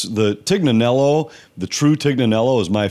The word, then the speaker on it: Tignanello: Yeah, no, the Tignanello. – Tignanello, yeah.